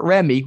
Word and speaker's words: Remy: The skier, Remy, which rhymes with semi, which is even better, right?